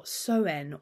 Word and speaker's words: Samhain: So it is, I've got it written phonetically here as well, Soen or Soen, the O pronounced the same as the O in owl, so, or cow.